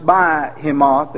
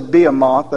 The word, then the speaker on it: Behemoth: And what is some people pronounce it Behemoth, and I'm going to say Behemoth, that's the alternate reading.